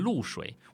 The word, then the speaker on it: 绿: 好，所以呢，我们看一下这阙词：花褪残红青杏小，燕子飞时绿水人家绕。啊，我在读的时候我读成露水，我现在念绿水，大家会不会不要说我读错了？